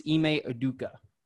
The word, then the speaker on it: Ime Udoka: He's just I was trying to get the pronunciation of the new coach. It's Ime Udoka.